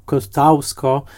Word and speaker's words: Kościuszko: No właśnie, jeżeli jacyś producenci nas słuchają, to pamiętajmy, że Kościuszko, co prawda wymawiane jako Kostausko,